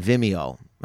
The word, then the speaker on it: Vimeo: And then Vimeo. I'm saying it right? – Yeah.